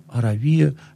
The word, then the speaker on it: Аравия: Ну, Аравия — это страна, не то, что мы сейчас называем Аравийский полуостров, там Саудовская Аравия. Аравия, я ставлю ударение так, как оно стоит в греческом языке, Аравии называли тогда область к востоку от, собственно, Палестины.